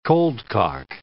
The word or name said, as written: coldcock